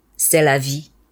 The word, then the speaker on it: c'est la vie: C'est la vie.